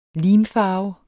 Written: limfarve

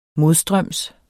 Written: modstrøms

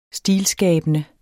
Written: stilskabende

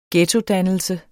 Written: ghettodannelse

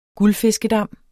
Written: guldfiskedam

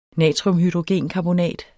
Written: NATRIUMHYDROGENKARBONAT

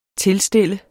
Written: TILSTIL

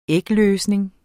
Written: ægløsning

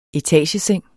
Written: ETAGESENGENES

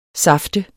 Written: saft

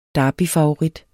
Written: DERBYER

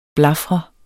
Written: blafre

